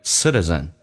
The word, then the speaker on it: citizen: citizen City T 说话成 D Citizen